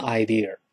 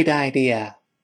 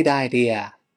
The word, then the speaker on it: idea: 没有字母 r，口型这个舌头舌头尖不能勾回来。跟老师读起来，idea，idea，注意不要读成 idea。我们一块读一下这句话：That's a great idea. That's a great idea.